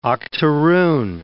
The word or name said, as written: octoroon